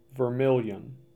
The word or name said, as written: Vermilion